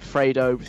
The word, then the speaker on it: Fredo: Before that, Dave and Fredo Funky Friday. I finally said it right, yay.